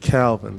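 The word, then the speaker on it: Kalvin: Find me at Calvin. That's Calvin spelled with a K.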